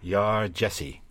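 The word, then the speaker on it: Yaa Gyasi: I found out this woman's name is pronounced Yar Jesse.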